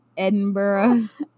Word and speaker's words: Edinburgh: I always say it wrong. It's Edinburgh.